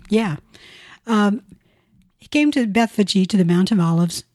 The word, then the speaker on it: Bethphage: That's why we see Bethphage mentioned. – Okay. And I you're right. That is the correct pronunciation. I'd always thought, looking at it from the vantage point of an English speaker, Bethphage.